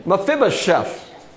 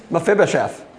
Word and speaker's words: Mephibosheth: Did I say that right? – Mephibosheth. – Right? Is that it? Mephibosheth. There we go.